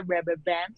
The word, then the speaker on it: Robovan: Luego también anunció la RoboVan, que se pronuncia RoboVan, según Elon Musk, y es como una especie de tren futurista, la parte de adelante es como triangular.